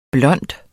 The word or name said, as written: blonde